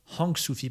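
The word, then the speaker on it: Hoàng Su Phì: Hang Sufi, plutôt avec le H prononcé, c'est ce qui se rapproche le plus de Sapa, je pense, dans l'imaginaire des Français.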